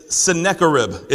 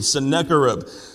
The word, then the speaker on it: Sennacherib: It's Sennacherib is the proper pronunciation. Sennacherib.